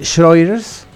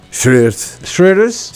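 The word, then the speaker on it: Schroeder: César Antero Roel Schroeders. – Schroeders. – Sí, ¿lo pronuncié bien o no? – Sí, muy bien.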